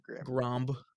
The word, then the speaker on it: Grambling: If I'm pronouncing that right, for all I know, it could be pronounced something different. Like Grom. – It's definitely Grambling.